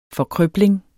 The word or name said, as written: forkrøbling